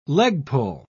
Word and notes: leg-pull: British English

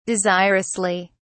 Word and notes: desirously: British English

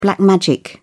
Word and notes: black magic: British English

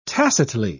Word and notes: tacitly: British English

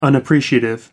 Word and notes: unappreciative: American English

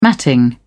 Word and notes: matting: British English

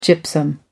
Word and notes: gypsum: American English

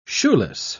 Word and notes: shoeless: British English

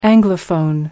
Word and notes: anglophone: American English

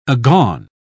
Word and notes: agone: British English

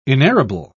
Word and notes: inerrable: British English

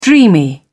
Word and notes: dreamy: British English

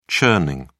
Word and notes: churning: British English

